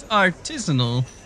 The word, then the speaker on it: artisanal: And by the way, it is artisanal. The locals seem to not know how to pronounce it.